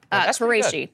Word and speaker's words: al-Qurashi: I'm going to totally butcher his name. I'm sorry, but his name is Abu Ibrahim al-Hashimi al-Qurashi. – Qurashi. – Qurashi. – Yeah. Okay. Yeah.